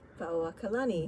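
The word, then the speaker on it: Lili'uokalani (Liliʻuokalani): It's the Ku'upua'i Lili'uokalani.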